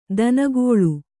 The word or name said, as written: ದಿನಗಳಿ